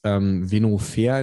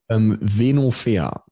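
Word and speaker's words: Venofer: Venofair. – Wie schreibt man das? Mit V wahrscheinlich? – V-E-N-O und dann F-E-R. Venofair. So heißt es.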